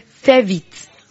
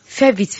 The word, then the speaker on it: fais vite: How do you say that in French? We say, fais vite. Fais vite, fais vite, fais vite, fais vite.